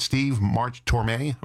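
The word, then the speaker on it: Steve March Tormé: Am I saying this right? Steve March Torme? – Right. – Steve March Torme.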